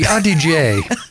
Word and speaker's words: Adige: And as you mentioned before, the Adige. – Adige. That's not an easy one to pronounce. Okay, so the Adige.